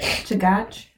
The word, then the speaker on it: Chugach: Chugach? I would pronounce it Chugach. – Yeah, we'll go with Chugach State Park.